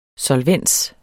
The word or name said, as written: solvens